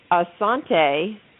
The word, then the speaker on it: Asante: one of which was Asante, hopefully I'm pronouncing that right, closed yep.